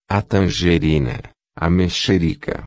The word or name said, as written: tangerina-mexerica